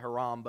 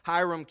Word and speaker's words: Hiram: Hiram, king of Tyre, some pronounce it Haram, but Hiram, king of Tyre, it says he sends David some messengers, workers, and some materials that are used to build David this immaculate palace.